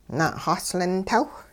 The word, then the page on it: na'xaslintawh: hupa